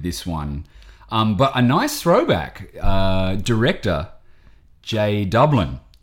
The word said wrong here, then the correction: Dubin: Jay Dubin? – I think it's Dubin. I think I pronounced it Dublin on the Pointer Sisters episode.